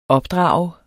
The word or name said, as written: opdrager